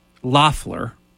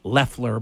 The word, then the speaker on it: Loeffler: Also, unfortunately, David Perdue, and I forget the woman's name who was the appointed senator, uh, Kelly Loeffler. Actually, I think they pronounce it Leffler, but it looks like Loeffler.